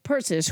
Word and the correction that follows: Perseus: who by the way is a Greek god, that's Perseus.